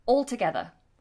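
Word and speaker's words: altogether: Now we do pronounce this L, all together, even though it might sound like we don't. We do. The tongue tip touches the roof of the mouth.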